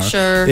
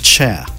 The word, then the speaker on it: Cher: You know, the thing is, a lot of people get her name wrong, like, because it's spelled C-H-E-R. – Sure. – A lot of people say sure, but if you really want to get it right, it rhymes with chair.